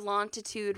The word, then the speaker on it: longitude: It's got a G in it. Longitude.